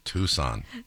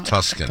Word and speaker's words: Tucson: Tucson. Not Tuscan.